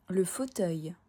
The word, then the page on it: Le fauteuil: French